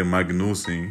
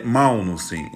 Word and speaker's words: Magnussen: Carlos Anovello já mora na Dinamarca há algum tempo Uh, e, e ele me confirmou que uh, a pronúncia de Magnussen, como dizemos aqui no Brasil, não tem problema nenhum dizer Magnussen, uh, é, é Malnussen em dinamarquês.